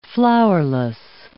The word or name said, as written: flowerless